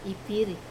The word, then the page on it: piri: wamesa